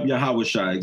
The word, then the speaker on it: Yahawashai: Also, from that same album in the song, Yahawashai, that's how you say it, right? – Yep, Yahawashai, exactly.